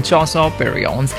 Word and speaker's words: Chansons: Yes, that's the French pronunciation, Chansons.